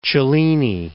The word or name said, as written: Cellini